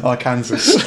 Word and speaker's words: Arkansas: No, they're the same place. That's how you pronounce it. Arkansas. – Oh, you pronounce it Arkansas. Oh, okay. Oh, there you go. – Yes, it's not Arkansas.